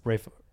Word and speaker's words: Ralph: So Ralph Fiennes plays... – Ray Fiennes. – Rafe. – He plays Roald Dahl. – But his name is Ralph. – No, it's pronounced Rafe.